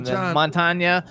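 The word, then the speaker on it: Mantegna: Montagna.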